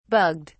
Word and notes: bugged: British English

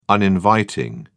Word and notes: uninviting: British English

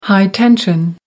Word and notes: high tension: American English

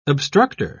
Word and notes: obstructer: British English

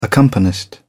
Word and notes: accompanist: British English

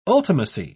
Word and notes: ultimacy: British English

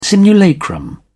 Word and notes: simulacrum: British English

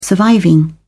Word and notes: surviving: British English

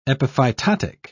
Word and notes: epiphytotic: British English

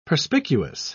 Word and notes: perspicuous: British English

